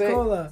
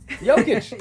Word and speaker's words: Nikola Jokic: Just Nikola. – Say Nikola. – Nikola. – Yeah, Nikola, man. – No, I want to get his name right. – Jokic.